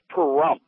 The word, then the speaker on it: Pahrump: How do you say that? – It's Pahrump. Sounds like there should be a rim shot directly following it. – Like it? – That song, Pahrump, bump, bump, bump, bump.